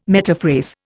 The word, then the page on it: metaphrase: Indian